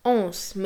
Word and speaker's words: Hans: Sogar die Tatsache, dass sie den Vornamen Hans, auf Französisch ausgesprochen Anse, mit France reimt, lässt man ihr durchgehen.